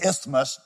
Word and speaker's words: isthmus: It was situated on this isthmus, that's how you pronounce that word,